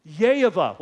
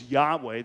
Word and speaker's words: Yehovah: Yehovah, what we often pronounce as Yahweh, that is not even close to the pronunciation.